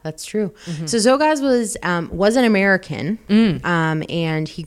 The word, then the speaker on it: Czolgosz: That's spelled Czolgosz. Very hard to pronounce. Czolgosz. – Was that Ukrainian? – It's a Polish name.